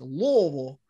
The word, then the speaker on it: Louisville: That's the right way to say Louisville, by the way. It's Louisville. I had to work on that for months.